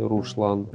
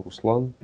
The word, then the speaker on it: Руслан: Значит, э, ну, имена Аслан и Руслан, они произносятся через С, если ну, так, на всякий случай. Тоже интересно было, ну, как бы, то есть не Ашлан и Рушлан, а Аслан Руслан, потому что это новые имена сравнительно, хотя Ашланбег через Ше произносится.